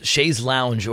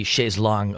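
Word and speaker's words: chaise longue: Chaise lounge or chaise long? Which side of the debate are you on? Yeah, apparently chaise long. Apparently the correct spell pronunciation as well. Long chair in French.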